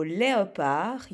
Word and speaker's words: léopard: Comme dans le mot léopard, il y aura un D à la fin du mot, on devrait écrire un D à la fin du mot, mais il ne se prononce pas.